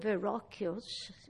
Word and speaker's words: Verrocchio: I pronounce it the Italian way, Verrocchio. It has two C's, H, I, O at the end.